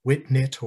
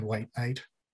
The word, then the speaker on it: White Knight: And it occurs to me, being the somewhat of an internet troll that I am, but you could also pronounce it Whitnit or White Knit.